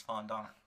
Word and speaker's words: fondant: It's a French word and the actual pronunciation is fondant.